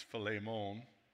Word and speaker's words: Philemon: Let's turn in our Bibles to Paul's letter to, and okay, this is how we historically know it as Philemon, but just so you know, to pronounce it correctly, it's Philemon, which I already told you makes my mouth water.